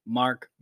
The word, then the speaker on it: Mark: It says it's pronounced here Marek Bouknight. – That's close. You know, very close. – Am I saying that wrong? – It's actually Mark Bouknight, but you know, so close.